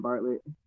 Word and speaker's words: Bartlett: B for my last name, Bartlett.